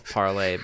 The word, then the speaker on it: Parler: Parler. Guns. Yeah, we can. I know and we can. can uh, well, I think it's, it's called Parlay, but it's spelled Parlor.